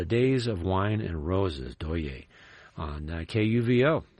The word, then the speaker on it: Douye: Yeah, we started off with another new album, that one from vocalist Doye, D-O-U-Y-E, with an emphasis on the E.